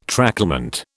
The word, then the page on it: tracklement: British English